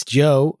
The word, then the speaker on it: Djo: That's Joe on D-Side. – And Joe is spelled D-J-O, if anyone's D-J-O, yeah.